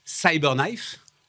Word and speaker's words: CyberKnife: Et, la grande force, je crois, c'est donc ce robot qui s'appelle Cyberknife.